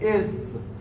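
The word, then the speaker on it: إذ: پس از درست مبنیه و مبنیه بر سکون هم هست، میگیم از، زالش رو ساکن تلفظ میکنیم، مبنیه، مبنیه بر سکون هم هست، ولی وقتی تو کلام مشاهده شد در این معنای ظرف بودنش، باید محلن منصوبش بدونیم.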